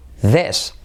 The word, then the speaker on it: this: This. Se escribe con i, pero se dice e.